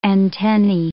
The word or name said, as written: antennae